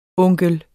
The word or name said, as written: onkel